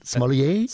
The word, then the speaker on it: sommelier: Did I say that right? Sommelier? – Sommelier.